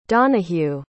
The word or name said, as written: donahue